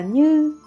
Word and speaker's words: như: Chữ nhập nên đọc là như, có nghĩa là đã qua.